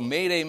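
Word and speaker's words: m'aidez: Mayday. – What is it? Yeah. It means what? Mayday. It sounds like mayday, and it's a French word then for? – Help me, right?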